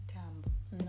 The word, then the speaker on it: dumb: Not dumb. No. You don't pronounce that B.